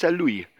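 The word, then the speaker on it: Saint-Louis: Äh, die San Luia, die war San Luisen, das heißt nicht San Luis, das heißt San Louis. Äh, das S wird eigentlich nicht ausgesprochen. Das weiß ich nämlich daher, weil ich dort nämlich 90, 91 Jahre lang gelebt habe.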